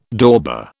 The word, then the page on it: dauber: British English